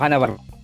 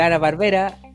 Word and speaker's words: Hanna: de los Ana Barbera. ¿eh? Sí, claro. – Ana Barbera. – Hanna Barbera, weón. Hanna. – Puta, ¿la H se pronuncia aquí en Chile? No.